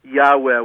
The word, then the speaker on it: Yahweh: And the answer is that none of the names that we have forgotten, not even Yahweh, which is the way scholars pronounce Yud-Heh-Vav-Heh,